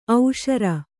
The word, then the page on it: ಓಸರು: Kannada